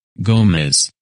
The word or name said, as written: Gomez